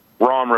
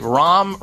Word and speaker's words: Rahm: ROM roast. – How do you spell that? – R-A-H-M roast. – All right, ROM roast.